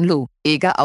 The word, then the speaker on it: Lueger: Und sie schreibt: Till, den Herrn Lüger spricht man Lo, Eger aus.